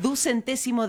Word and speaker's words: ducentésimo: Para, ducentésimo, decimosexto.